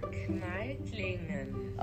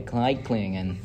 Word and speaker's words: Kneitlingen: And so, yeah, our character, our trickster figure, Till Eulenspigel, was born in the northern part of Germany in a town called, oh, that is hard to pronounce, Klinklang. Hold up. What does that say? – Kneitlingen. – Okay, Kneitlingen. That's what it is.